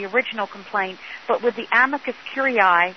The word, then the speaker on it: amicus curiae: Now, ACB, there is something called an amicus curiae. Have I said that correctly? – Yes, that's the letter that we're expecting from ACB and from the guide dog schools.